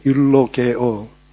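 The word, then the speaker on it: 율로게오 (εὐλογέω): 여기 나오는 축복하다라는 말은 율로게오라고 헬라어 단어가 발음이 되는데 율로게오.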